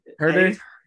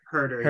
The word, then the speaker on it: Huerter: Oh, I, I, genuinely didn't know how to say it. – Herder. – Herder. Herder. Yeah.